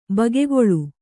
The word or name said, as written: ಭಾಗಗೊಳ್ಳು